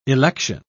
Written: election

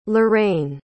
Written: loraine

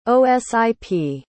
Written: osip

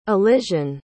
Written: elysian

